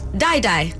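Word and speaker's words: Dai Dai: It's either Day Day or Die Die. It's spelled D-A-I. – D-A-I. Die Die.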